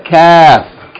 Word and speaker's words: kaf: The letter kaf. Kaf. Remember, kaf should be distinguished from qaf. Qaf is pronounced at the very end of the mouth before the throat, and kaf is just a little bit before.